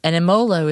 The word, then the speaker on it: Emmolo: And Emolo is spelled E-M-M-O-L-O.